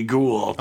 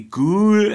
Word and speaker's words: ghoul: I mean, it's G-H-O-U-L, which could be foul just as easy as it could be ghoul. – But he heard me keep saying that, you know, Rudy was a ghoul. He's like, oh, it's that word, you know. And he now is like, he's like, how do you say ghoul? And like, it's like cool with a G, ghoul. – Yeah. – And he's like, cool. And it's like ghoul. He's like ghoul.